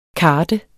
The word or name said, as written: kart